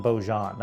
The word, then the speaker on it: Baujan: B-A-U-J-A-N. Baujean. – John Baujean. That's like Jean Valjean.